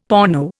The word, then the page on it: porno: Indian